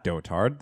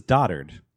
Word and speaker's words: dotard: This is not dotard. This is dotard. You pronounce that word dotard. – Fuck that. Dotard. – Is it D-O-T-A-R-D? – Don't even, yeah.